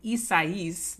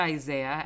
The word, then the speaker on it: Isaias: Today is actually a week after storm, as I learned later and I mispronounced it, Tropical Storm Isaias, not Isaiah, as I said earlier.